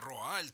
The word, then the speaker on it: Руаль: Ну, по-разному в разных источниках читается его имя. Руаль. Вот, норвежский полярный исследователь, да, героический совершенно мужчина.